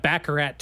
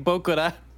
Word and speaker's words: baccarat: Baccarat tables, but they kept pronouncing it Baccarat. – Like Bokura.